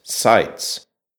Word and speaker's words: Zajec: In the German language, the Slovene word for a rabbit, Zajec, is written with an S and pronounced Seitz.